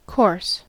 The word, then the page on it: course: American English